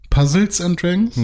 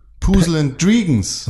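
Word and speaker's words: Puzzle & Dragons: Puzzles and Dragons? Nee. Puzzle and Dragons. Ach so. Oder Pad, sagen auch viele.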